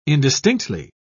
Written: indistinctly

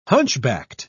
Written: hunchbacked

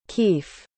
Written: kief

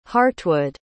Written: hartwood

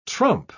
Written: trump

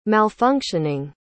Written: malfunctioning

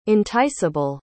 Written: enticeable

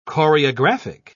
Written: choreographic

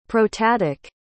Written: protatic

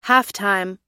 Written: halftime

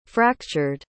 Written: fractured

